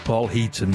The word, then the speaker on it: Paul Heaton: First of which is this one as well, and it's Paul Hatton, and, did I pronounce that right then? It's called Fish and Chip Supper, anyhow. Paul Heaton, perhaps, yeah.